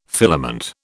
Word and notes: filament: British English

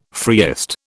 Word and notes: freest: British English

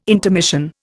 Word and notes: intermission: Indian

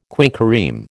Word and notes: quinquereme: American English

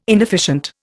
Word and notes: inefficient: Indian

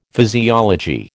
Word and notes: physiology: American English

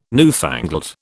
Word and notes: newfangled: British English

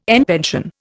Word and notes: en pension: Indian

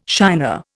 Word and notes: shiner: Indian

